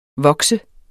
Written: VOKSEDES

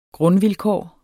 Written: grundvilkår

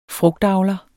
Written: frugtavler

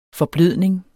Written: forblødning

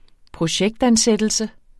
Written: projektansættelse

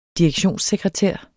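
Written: DIREKTIONSSEKRETÆREN

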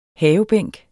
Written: havebænk

